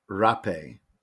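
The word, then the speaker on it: Rapé: But I think it is pronounced rape, but the rapey pronunciation led us here to try and say it differently. But the Taisha that I know that uses it prior to ayahuasca ceremonies, he calls it rape.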